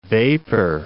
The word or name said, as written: vapor